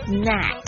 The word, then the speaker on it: snack: 所以如果你带蛇上飞机呢，我不知道是不是有办法带蛇上飞机，那不要讲错，讲错就会很尴尬，所以是 snack。这个 A 这个发音呢，是发那个音标长得像蝴蝶的那个鸭舌的音，是不是鸭舌头？